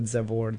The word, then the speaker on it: Zabor: It kind of ended up being a spin-off of what Zabor Dance is. By the way, Zabor is spelled Z-A-B-O-R because it's a combination of three words. Saber means to know, sabor means flavor, and the Z comes from zest.